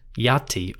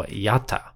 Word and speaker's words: Aten: For a word like Aten or I-T-N, that final N was probably left off, and the word would be pronounced Yati or Yata.